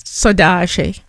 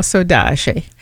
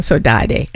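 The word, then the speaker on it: saudade: And I found one piece called Sodage. And at the first, I didn't know how to pronounce it. I was saying Sodade until I met some Brazilians who explained how to say it.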